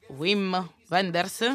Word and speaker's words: Wim Wenders: Primo penso che sia Il cielo sopra Berlino di Wim Wenders,